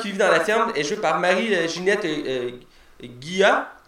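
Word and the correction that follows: Guay: Gay. excusez-moi, je sais pas pourquoi je l'ai mal prononcé. Gay, qui joue Thérèse qui est L'autre Vieille, puis aussi je la connais, puis ces hein.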